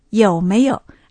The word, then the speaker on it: 有没有: 有没有出去？ – おお、まず、有没有。台湾ではよくこういうふうに聞くんですね。ある。また次は否定形のない。没有。有没有。ありますか？っていう意味ですね。有没有。有の発音は YOU。賛成です。